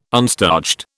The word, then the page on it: unstarched: British English